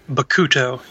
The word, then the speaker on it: Bakuto: Bakuto? Is that right? – Bakuto, yeah.